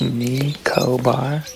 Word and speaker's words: Nicobar: N-I-C-O-B-A-R. Are we saying that right? Nico bar? – Nico bar is my best guess, yeah. – Yeah, that's how you pronounce it.